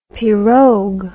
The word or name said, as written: pirog